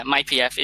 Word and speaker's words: mypf: Yeah, mypf.